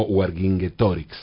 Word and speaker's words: Vercingetorix: El mayor líder de los galos fue Vercingetorix, o Wargingetorix, según la, pronunciación en latín, cuyo nombre aparece mencionado en Asterix.